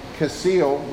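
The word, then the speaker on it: kesil: We have that first one, Evil, and then we have this one, Casil, which rhymes with Evil.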